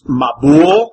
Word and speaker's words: Mabul: The Hebrew word, and since you don't know Hebrew, that doesn't mean anything to you, but it's pronounced Mabul. So if you wanted to transliterate it, it would be M-A-B-U-L, Mabul.